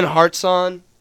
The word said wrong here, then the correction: Hartshorn: Hartshorn. Ben Hartshorn. – Yep, mispronunciation, but hey, shout out to you, my man.